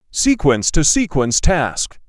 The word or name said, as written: Sequence-to-sequence task